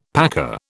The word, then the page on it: paca: British English